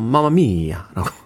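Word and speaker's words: Mamma mia: 맘마미아. 이태리 사람들은 맘마미아 이렇게 하라고맘마미아 이렇게 이태리도 사성이 있는지 모르겠습니다만 중국어처럼 예, 그 독특한 아주 그 발음이 있어요. 마마미야라고.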